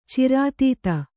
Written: ಚಿರಾತೀತ

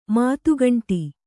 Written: ಮಾತುಗಂಟಿ

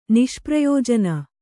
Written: ನಿಷ್ಪ್ರಯೋಜನ

